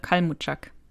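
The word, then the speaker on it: Kalmuczak: aber ich kann den Namen nicht aussprechen, Rolf Kaczmarek oder so. – Kalmutschak, glaube ich, Ralf Kalmutschak.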